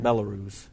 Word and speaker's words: Belarus: That's how you say that, Belarus. Yeah, that's how you say it.